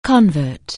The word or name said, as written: convert